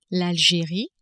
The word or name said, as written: Algerie